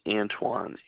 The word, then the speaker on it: Antoine: James Antoine. A-N-T-O-I-N-E.